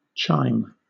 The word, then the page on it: chime: British English